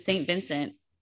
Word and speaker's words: St. Vincent: Speaking of the Grammys and losing nominations, Taylor Swift, Jack Antonoff, and St. Vincent.